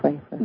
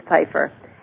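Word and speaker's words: Pipher: It even begins with P, but I think it's Pfeiffer. – Yes, it is Pfeiffer.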